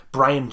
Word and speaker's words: Brion: Brian James. – It's spelled B-R-I-O-N, though. Like, it's spelled different.